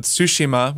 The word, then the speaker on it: Tsushima: And it was only like a day after I'd gotten a code for Ghost of Tsushima, which I now know is how it's pronounced.